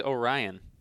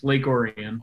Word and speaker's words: Orion: It's Orion? Yep. How do you pronounce it? I thought it was always Orion. – No, it's Lake Orion.